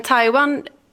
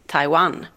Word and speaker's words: Taiwan: Men Taiwan, alltså det säger jag ju så eftersom att det är så man lägger betoningen när man säger det på kinesiska.